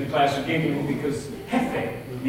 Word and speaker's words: Jefe: So instead, when I took Spanish in school years and years ago, was pronounced the J, it's like an H, and so it's pronounced the teacher. She said, okay, your Spanish name will be Jefe.